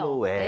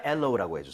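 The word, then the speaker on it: aloe: 그죠, 이게 파닉스대로 근데 읽으면 알로에 느낌이 나긴 해요. – 알로에. 네. 하지만 엘로우라고 해주세요.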